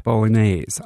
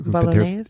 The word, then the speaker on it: Bolognese: And also Machine Shop Bolognese. I'm not pronouncing that right. – Bolognese? But there you go.